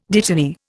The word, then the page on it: dittany: Indian